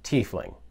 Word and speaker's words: tiefling: Christopher Perkins from D&D has also confirmed that the pronunciation of the word is tiefling.